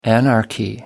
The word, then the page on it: anarchy: American English